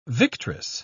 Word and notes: victress: British English

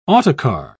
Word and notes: autocar: British English

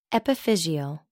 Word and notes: epiphyseal: American English

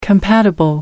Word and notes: compatible: American English

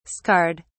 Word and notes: scarred: British English

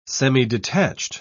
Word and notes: semidetached: British English

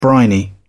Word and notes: briny: British English

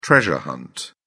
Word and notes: treasure hunt: British English

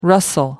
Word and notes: rustle: American English

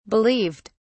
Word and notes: believed: British English

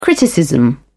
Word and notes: criticism: British English

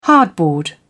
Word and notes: hardboard: British English

